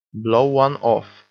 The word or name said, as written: blow one off